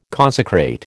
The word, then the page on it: consecrate: American English